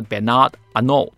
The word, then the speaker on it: Bernard Arnault: 哦，我还特别去查了查了一下这个发音，就是到底用法文说的话，伯纳德阿诺特要怎么发音呢？就是 Bernard Arnault。 OK，这个结尾这个 T 好像是不发音的。